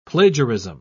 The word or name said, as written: plagiarism